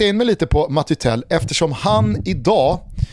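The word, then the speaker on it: Mathys Tel: Han heter då alltså M-A-T-H-Y-S och så i efternamn T-E-L. Det gör att min franska vill liksom uttala det Matti Tell. Alltså jag tror inte, fransman. – Jag tror inte man uttalar det Mathis direkt. – Okay. – Men så att Matti Tell tror jag är det korrekta franska uttalet på den här 17-åriga anfallaren.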